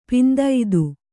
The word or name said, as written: ಪಿಂದೈದು